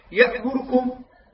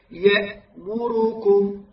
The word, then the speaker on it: يأمركم: يختلس الضمة ولا ينطقها بصورة صحيحة. هكذا يفعل. يأمركم. لاحظ يأمركم. لا خطأ هذا أخوان. يجب أن تأتي فترة زمنية صحيحة ولا تختلس الحركة. لا تأتي بجزء حركة. هكذا تقرأها. يأمركم.